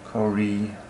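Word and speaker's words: Kore: Kor was her other name. It means maiden, as in first. – I nod, stupidly, trying not to wince at his horrible pronunciation. Kori, old man, not Kor, and Demeter was never a moon goddess.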